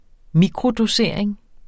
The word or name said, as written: mikrodosering